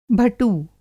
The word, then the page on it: भटू: Hindi